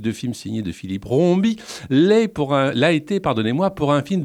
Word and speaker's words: Rombi: le dénommé Philippe Rombi,